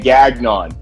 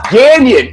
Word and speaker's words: Gagnon: You pronounce my last name Gagnon. It's Gagnon. It's French Canadian.